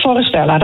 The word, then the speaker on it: Forestella: Want ik kende Forestella eigenlijk helemaal niet. Spreek je het zo goed uit eigenlijk? Of, Ja, helemaal goed. Forestella, dat klopt.